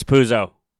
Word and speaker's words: Puzo: When it comes to Italian pronunciation, if it's two Z's, it's how you pronounce it. Zoop, like pizza. Mm-hmm. You wouldn't say pizza. – Right. – So if it's one Z, it's Puzo.